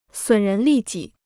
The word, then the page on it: 损人利己: sǔn rén lì jǐ